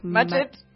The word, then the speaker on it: Mudgett: And he was actually born Herman Webster Mudgett. I think that's how you say it. – Mudgett. – Mudgett.